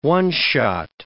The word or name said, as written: one-shot